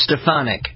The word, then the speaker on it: Stefanik: Elise Stefanik. And that is, by the way, how she pronounces her name. I've heard her say her own name, and that's how she says it. Stefanik. And you can write it out. It's S-T-E-F and then A-H, capital, because that's where the emphasis is.